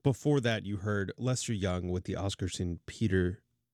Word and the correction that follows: Oscar Peterson: Trio. I feel like I didn't say that right. Oscar Peterson Trio.